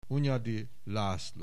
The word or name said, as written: Hunyadi Laszlo